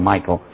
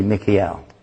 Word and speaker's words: Mikael: Mikael, called Michael in English. They mispronounce it, call him Michael. Mikael. If you know anybody named Mike or Michael, comes from the Hebrew word, actually, Mikael.